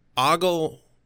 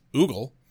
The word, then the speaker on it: ogle: There's no ogle, ogle and ogle are both, like, ogle is more correct than the way I had been pronouncing it, which was oogle, but no, I have it wrong. I've been pronouncing it wrong my whole life.